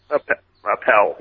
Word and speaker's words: Appel: I think there is Mark Appel or Appel. How do you say that? – Appel. Yeah, Mark Appel.